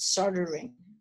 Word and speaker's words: soldering: I think you pronounce it soldering. We pronounce the L.